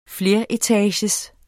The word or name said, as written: fleretagers